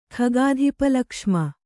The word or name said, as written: ಖಗಾಧಿಪಲಕ್ಷ್ಮ